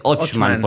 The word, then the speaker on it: Ochman: Es Ochman, porque tiene una H.